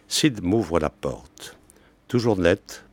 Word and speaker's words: Cyd: Alors là, nous sommes dans Femmes, avec un personnage important du livre, qui s'appelle Cyd, qui s'écrit C-Y-D, comme la danseuse très célèbre qui s'appelait Cyd Charisse, dont les jambes sont encore dans les mémoires attentives.